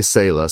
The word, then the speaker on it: Sala: There's a couple different ways to say it. We say Sala, so it means to pause and reflect.